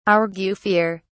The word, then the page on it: argufier: British English